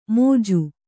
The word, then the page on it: ಮೋಜು: mōju